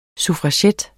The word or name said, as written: suffragette